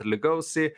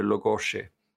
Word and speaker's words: Lugosi: Det är också sina Massacre tog upp det, för jag har ju alltid uttalat det Lugosi, men tydligen är det Lugosi.